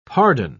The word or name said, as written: pardon